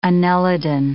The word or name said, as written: annelidan